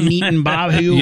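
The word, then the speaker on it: Netanyahu: That are knitting Bob or BB, is it BB Neaton Bobby? How you say it? You got it. – BB Neaton Bahu. – Yep. Nailed it.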